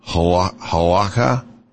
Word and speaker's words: halakha: Jewish law, halakha, did I say that right? – Yeah.